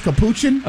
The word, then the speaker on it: capuchin: What kind of monkey you say was capuchin? – A capuchin.